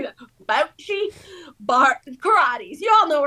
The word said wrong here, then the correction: bocce: Bocce. Oh, am I saying that right? I'll let me consult our resident Italian. Is it bocce? – That's right, bocce.